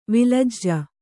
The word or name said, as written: ವಳಜ